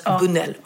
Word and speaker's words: Busnell: Heter det Bunell eller Busnell? Jag tror det heter Bunell. – Ja. – Det stavas Busnell, men jag tror det uttalas Bunell.